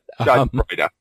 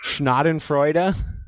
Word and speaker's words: schadenfreude: I don't know how to pronounce it, but schadenfreude. – Schnadenfreude? – Schadenfreude.